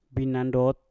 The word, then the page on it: binandot: mpur